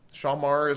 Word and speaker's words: shamar: Shamar is another Hebrew word, and I'll spell that for you as well. It's S-H-A-M-A-R.